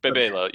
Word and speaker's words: Bebelo: Bibelo, I think, is actually the pronunciation. – Bibelo. – I thought Steve McPherson tweeted out that it was Bibelo. – Yeah, in Spanish, it wouldn't be BB. It would be Bebe.